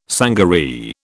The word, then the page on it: sangaree: British English